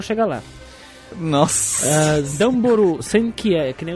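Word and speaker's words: Damboru Senki (ダンボール戦機): O nome do jogo é Dumbal Senki, conhecido aqui no ocidente como Little Butler's Experience. na verdade a pronúncia é Damboru, Damboru Senki, em japonês significa algo como as máquinas uh, lutadoras, máquinas de guerra da caixa de papelão.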